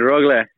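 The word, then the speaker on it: Rögle: How do you say the name of the team that you play for? – Yeah, it's always good, Rogle.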